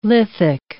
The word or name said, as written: lithic